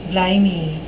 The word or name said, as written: blimey